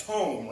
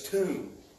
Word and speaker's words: tomb: They're spelled like comb, C-O-M-B, and then he flipped the thing down in a T, and he said, so that's tome, right? No, it's tomb.